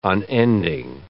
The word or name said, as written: unending